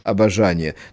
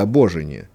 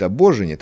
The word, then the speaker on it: обожение: Ну, тут Достоевский так своеобразно произносит понятие богословское – обожение. То есть вообще цель жизни, цель бытия человеческого. Вот при такой только вере мы достигнем, ну, я буду правильно говорить, обожения, того восторга, который наиболее приковывает нас к нему непосредственно и имеет силу не совратить человека в сторону.